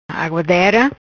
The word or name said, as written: Aguadera